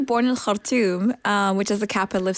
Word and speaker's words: Khartoum: Say it again in the correct way. – Khartoum. – That's a brilliant pronunciation.